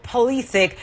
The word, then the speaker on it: Pulisic: It's a big loss, but it turned out to be no Pulisic, no problem for, that's how you say it, for Team USA.